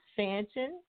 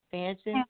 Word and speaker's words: Fanchon: My name is Fanchon. It's pronounced like mansion, but with an F, Fanchon.